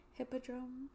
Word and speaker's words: Hippodrome: Hippodrome. – H-I-P-P-O? – Yeah, like hippo. Okay. And then drome.